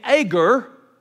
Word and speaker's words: Agur: one of which is named Agur, son of Jaka. Now, some translations spell his name A-G-A-R, Agar. I'm using New International, and it uses Agur, the son of Jaka, but either pronunciation is acceptable.